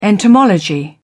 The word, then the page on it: entomology: British English